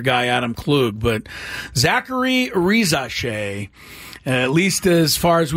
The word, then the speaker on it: Risacher: But it's looking like Rizache. Re, which is the R-I part. Za, S-A. And then C-H-E-R is pronounced She.